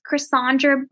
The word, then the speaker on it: Chrysandra: And you can go find me at any of my social media sites, Chrysandra Brunson. And it's spelled a little differently, C-H-R-Y-S-A-N-D-R-A.